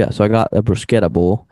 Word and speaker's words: bruschetta: It's called a bruschetta bowl, or actually I heard actually the proper pronunciation of bruschetta is bruschetta bowl.